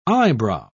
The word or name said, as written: eyebrow